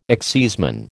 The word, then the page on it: exciseman: American English